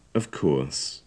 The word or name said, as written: of course